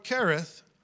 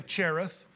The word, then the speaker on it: Cherith: We would say Cherith. The Old Testament pronunciation would be Cherith, but that is almost a transliteration of the word that means to cut.